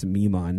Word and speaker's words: Mi'kmaq: That's how they pronounced it then. I believe it's Mi'kmaq now.